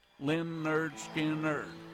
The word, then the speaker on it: Lynyrd Skynyrd: Well, we're going to break it out for you right here with a tune from 1973 off of an album called Pronounced Lynn Nerd Skinner Nerd. Here's Lynyrd Skynyrd and Give Me Three Steps.